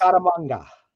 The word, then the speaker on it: Scaramanga: Ah, or Scaramanga. Sorry, mispronounced. – Scaramanga.